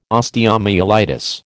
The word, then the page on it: osteomyelitis: American English